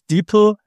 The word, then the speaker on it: DeepL: DeepL, ja.